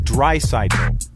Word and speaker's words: Draisaitl: Leon Draisaitl. – Draisaitl? – Leon Draisaitl.